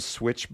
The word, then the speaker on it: Swytch: I haven't done enough research to have that for you, but it's simply called Switch Bike, and it's spelled S-W-Y-T-C-H Bike.